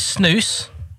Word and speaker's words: snus: Oh, so what is snooze? And why are some Norwegians obsessed with snooze? – That's a very good question, and you said it right. Snooze.